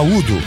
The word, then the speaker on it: Udo: Gente, ó, mais um bloco, nesse bloco, faixa do último álbum do Accept, o Accept do UGO, deixa eu só falar Udo, mas são três letras, quando são três letrinhas assim, você tem que pronunciar as letras, U-D-O, UGO. Bom, agora o pessoal junta tudo e fala Udo, assim como o UFO, o pessoal fala UFO, tá?